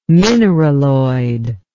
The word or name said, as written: mineraloid